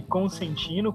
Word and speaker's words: Consentino: E também no Instagram da doutora, o arroba Maria underline Consentino, com S. Falei certo, doutora? Sim!